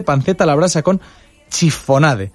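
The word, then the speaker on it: chiffonade: Que no sé si lo he dicho bien, y ahí puede corregirme, pues su autor Mario Obrador. ¿Qué tal? Buenos días. – Buenos días. Sí, lo ha dicho bien. Chifonade.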